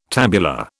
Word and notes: tabular: British English

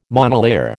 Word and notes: monolayer: American English

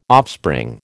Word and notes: offspring: American English

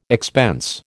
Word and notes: expense: American English

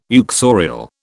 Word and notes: uxorial: British English

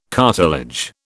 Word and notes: cartilage: British English

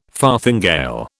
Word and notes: farthingale: British English

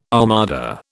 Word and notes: armada: British English

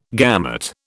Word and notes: gamut: British English

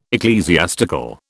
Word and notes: ecclesiastical: British English

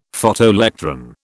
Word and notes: Photoelectron: British English

